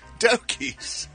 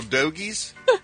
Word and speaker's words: dogies: Dogies. Get along, little dogies. – Why do you say it with a Philly accent? – He says it like he says hoagies. – That's how it's pronounced.